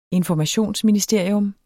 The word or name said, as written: informationsministerium